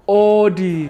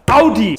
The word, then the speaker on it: Audi: So, the first wrong car name, so many people say Audi, when it's actually pronounced Audi. Say it with me. Audi.